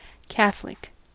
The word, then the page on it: catholic: American English